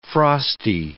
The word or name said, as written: frosty